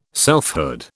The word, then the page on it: selfhood: British English